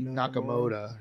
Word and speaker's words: Nakamoto: Satoshi Nakamoto.